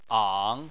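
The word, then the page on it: ang: tone 3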